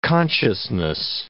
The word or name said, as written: consciousness